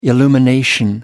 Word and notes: illumination: American English

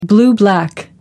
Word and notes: blue-black: American English

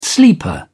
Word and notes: sleeper: British English

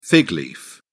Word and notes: fig leaf: British English

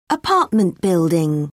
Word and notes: apartment building: British English

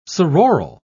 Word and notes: sororal: British English

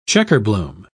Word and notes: checkerbloom: British English

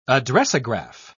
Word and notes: addressograph: British English

